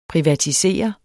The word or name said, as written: privatisere